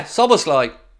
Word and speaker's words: Szoboszlai: Yeah, Szoboszlai.